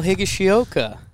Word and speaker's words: Higashioka: It's more like a Spanish pronunciation, so the Latin guys say it perfectly. – Oh, really? – And the American guys have trouble with it. – It's pretty funny. – Oh, damn. So what's it with the Spanish flair? – Well, like the vowel sounds are exactly the same. So like Higashioka. – Oh, not Higashioka. – The I's sound like E.